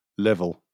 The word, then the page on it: level: British English